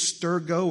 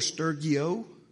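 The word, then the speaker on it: storge: Two pronunciations, stergo or stergio.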